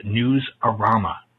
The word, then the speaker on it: Newsarama: It is Newsarama. It's Arama anyway. No one says Rama. It makes it something else.